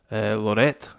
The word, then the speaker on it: Laureate: Cause so the barley they use is Concerto, and I have a really bad pronunciation of this, and you can correct me. I'd say Lorette. – Lorette. – L-A-U-R-E-A-T-E.